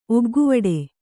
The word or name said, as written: ಒಗ್ಗುವಡೆ